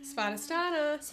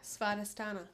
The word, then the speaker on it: Svadhisthana: What's the pronunciation? – Svadhisthana. – Sva. – De. – Sa. – Sa. – Na. Sva. Well, there's a T in there. – Yeah, Svadhisthana.